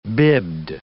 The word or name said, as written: bibbed